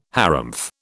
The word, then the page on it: harrumph: British English